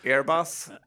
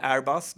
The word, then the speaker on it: Airbus: Airbus eller Airbus, det beror på hur man vill uttala det.